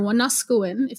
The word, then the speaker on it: Wanuskewin: So my master's thesis is focusing on plains archaeology, of course, specifically around the Apimahau Valley, which is the valley where Wanuskewin or Wanuskewin, if you say it properly in Cree, is situated.